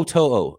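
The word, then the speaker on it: To'oTo'o: Toto. – Okay, yeah, you're right. I've heard it said correctly, and I've not said it correctly, of course.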